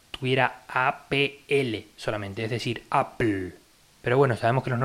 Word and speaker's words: Apple: En inglés hay muchas palabras que tienen una consonante y luego LE, como puede ser paddle, Wimbledon, Apple mismo, o lo que sea, que se escriben como si fueran al revés. Es decir, Apple se escribe Apple, pero se pronuncia Apple.